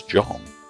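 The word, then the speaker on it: Dgohn: Though spelt D-G-O, capital H-N, John Kinane's musical moniker of choice is apparently just pronounced as John.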